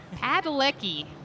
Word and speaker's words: Padalecki: Padalecki. – Padalecki.